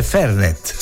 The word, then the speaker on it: Fernet: In toscana si dice Fernet. Ah sì, accento sulla prima E. Davvero? Vabbè, la pubblicità però diceva Fernet.